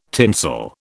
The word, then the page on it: tinsel: British English